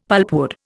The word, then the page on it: pulpwood: Indian